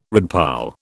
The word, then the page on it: redpoll: British English